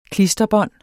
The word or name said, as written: klisterbånd